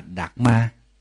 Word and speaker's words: Dharma: Thì xin nhắc lại, cái chữ Pháp đó, theo tiếng Phạn đọc là Dhamma. Tiếng Pali đọc là Dhamma, còn tiếng Phạn, tiếng Sanskrit đó, đọc là Dharma.